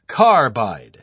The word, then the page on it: carbide: British English